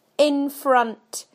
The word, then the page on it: in front: British English